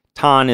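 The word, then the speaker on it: Tan (たん): Tan is like Chan, basically. It's like a more childish pronunciation of Chan.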